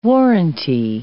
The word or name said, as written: warranty